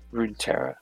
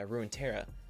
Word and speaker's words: Runeterra: Runeterra, correct, Shiloh? Runeterra wise, the League of Legends, how would you say it? The League of Legends... Universe. – Runeterra. – Yeah, Runeterra.